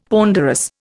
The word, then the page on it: ponderous: Indian